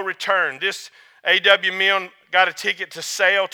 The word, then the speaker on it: Milne: It's a man named A.W., I believe he pronounced his name Milne, M-I-N-M-I-L-N-E, Milne, Milne, whatever.